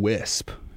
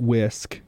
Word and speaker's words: Wisp: We're going to play some Wisp. W-I-S-P. Not Whisk for you people who are telling me that's how it's pronounced. I looked it up. It's not pronounced Whisk, even though it's spelled W-I-S-P.